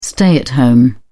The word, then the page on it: stay-at-home: British English